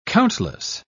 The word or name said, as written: countless